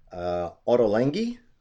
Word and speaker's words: Ottolenghi: And I know for a while you were working at a famous restaurant in London, and I hope I don't mangle the pronunciation, Ottolenghi. – Yeah, that's correct.